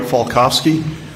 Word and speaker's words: Falkofske: My name is Dwight Falkowski. Last name is spelled F-A-L-K-O-F-S-K-E.